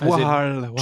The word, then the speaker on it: Wahal: But you put an extra A in, didn't you? It's wall, as in Wahlberg. – Oh, it's wahl. – Choice. – It's wahl. It's Wahlberg. It's quite narwhal. It's in the narwhal word sort of area because it's W-A-H-L, wahl.